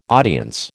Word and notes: audience: American English

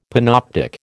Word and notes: panoptic: American English

